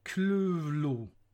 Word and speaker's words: Cthulhu: Namnet ska, enligt vissa, uttalas Cthulhu Enligt ett av de brev som Lovecraft skrev är det dock så här. Själva ljudet, så som mänskliga stämband kan imitera det eller mänskliga bokstäver skriva det, blir det ungefär kluvlo, där det första uttalas gutturalt och väldigt tjockt.